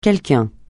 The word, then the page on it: quelqu'un: francais